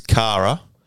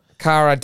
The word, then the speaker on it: Cara: Chiara Delavive. – Let me have a look. It's Cara. – Cara Delevingne.